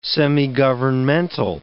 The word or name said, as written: semigovernmental